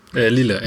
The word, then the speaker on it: Lille: Also ich gehe mal noch ins Weiter, noch mal kurz zum Ille zurück. Lille, Entschuldigung, jetzt habe ich es auch noch falsch gesagt.